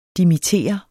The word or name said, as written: dimittere